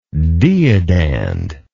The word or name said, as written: deodand